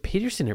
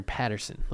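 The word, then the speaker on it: Paterson: Is it Peterson or Patterson? Let's debunk this myth right here. It's Patterson. Yeah, I've been saying it wrong this whole fucking time. It's Patterson.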